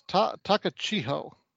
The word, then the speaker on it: Takachiho: Akahisa Takachish. Oh, well, fuck. The Great Kabuki. Okay. – Is it Takachiko? – I believe that's what it's. Takachiho. Akahisa Takachiho. I believe it's how it's pronounced.